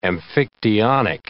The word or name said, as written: amphictyonic